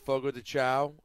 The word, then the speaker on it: Fogo de Chão: That's how you pronounce that, right? – That's right, Fogo de Chão.